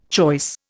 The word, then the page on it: choice: Indian